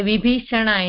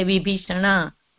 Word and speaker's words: Vibhishana: Like, they say Vibhishana in South, they say Vibhishan is a Pullinga, we say Vibhishan, but they say Vibhishana, like that. – Vibhishana is the Sanskrit way of pronouncing. In Hindi, it becomes Vibhishan.